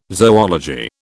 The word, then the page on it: zoology: British English